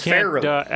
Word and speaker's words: Farrelly: No, you it's Farrelly.